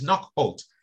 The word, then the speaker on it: Knockholt: He's just So he basically said, it's Knockbolt. And then people just laughing. So he said to himself, Knockbolt. And they said, no, it's not Knockbolt. The correct name is Knockholt.